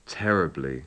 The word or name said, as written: terribly